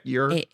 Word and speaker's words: Eure: Another thing that I could not shake is that the actor playing Will, his last name is spelled E-U-R-E. Would you spell your? – Yeah, your.